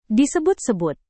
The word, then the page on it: disebut-sebut: Indonesian